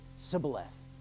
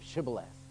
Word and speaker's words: Shibboleth: And they found out that the enemy didn't pronounce the SH, the SH. And so they would say Sibboleth instead of Shibboleth.